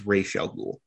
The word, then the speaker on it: Ra's al Ghul: Okay, so first of all, and this is very minor, but they're pronouncing Ra's al Ghul's name wrong. It's Ra's al Ghul, but he's Ra's in this.